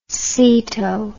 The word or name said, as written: ceto